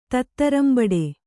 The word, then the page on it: ತತ್ತರಂಬಡೆ: tattarambaḍe